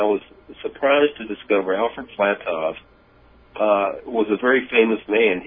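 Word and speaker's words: Flatow: So when I found that name, the reason I'm saying Alfred Flattog, you say Flatto, is because a W is pronounced as a V in German.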